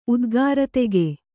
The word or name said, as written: ಉದ್ಗಾರತೆಗೆ